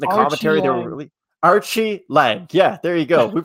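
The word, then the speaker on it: Aori Qileng: So Archie Lang. That's how you say his name. I didn't know that, and Craig taught me. So it's Archie Lang.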